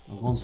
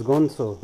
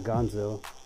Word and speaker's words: Gonzo: Is it Gonzo? – Gonzo. – So it's Gonzo. Not Gonzo. It's Gonzo.